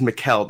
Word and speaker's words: Mikel: Insalaco's right. My first name is Mikel, though.